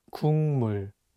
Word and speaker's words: 국물: The consonant 기역 is for G sound in English, so you can read it as 국, but it's paired with the nasal sound 미음, so it changes the pronunciation from a G sound to an NG sound, and you'll need to read it as 국물.